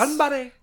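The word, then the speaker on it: Ganbare (頑張れ): Ganbare, right? – Yeah. – Like it's, Ganbare.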